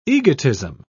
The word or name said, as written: egotism